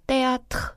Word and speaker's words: théâtre: 보시면 으 위에 악성 떼기가 붙어 있어서 에 발음이 따로 날 거고요. – 네. – 그렇기 때문에 아도 발음이 따로 나요. 그래서 le théâtre 하고 읽습니다.